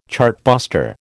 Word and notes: chartbuster: American English

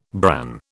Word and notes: bran: British English

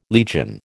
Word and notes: legion: American English

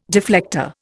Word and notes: deflector: Indian

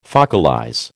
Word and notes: focalize: American English

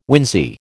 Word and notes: wincey: American English

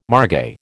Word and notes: margay: American English

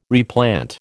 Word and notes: replant: American English

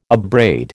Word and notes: abrade: American English

